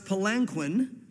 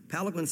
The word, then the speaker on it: palanquin: It's actually, I think, pronounced palanquin. Palanquin sounds better to me, but palanquin.